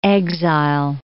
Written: exile

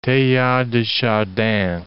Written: Teilhard de Chardin

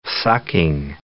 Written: sucking

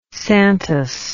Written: Santos

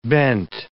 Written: bent

